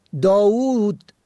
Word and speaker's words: داوود: واژه ها یا کلماتی مانند داوود با دو واو، کاووس با دو واو و مانند آنها که با اشبا تلفظ می شوند در نگرش پارسی با دو واو، یکی به گونه صامت و دیگری به گونه مصوت بلند